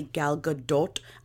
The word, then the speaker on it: Gal Gadot: But whenever I pronounce it correctly, Gal Gadot, I sound crazy.